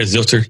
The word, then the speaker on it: Zylter: Is that how you say it, Zylter? – Mm-hmm.